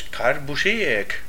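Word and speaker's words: Kurt Busiek: Karbasek. – Yo voy a seguir diciendo Karbasek, porque como Forme lo ha dicho, me parece muy complicado de... – No, es muy... Es Karbusiek.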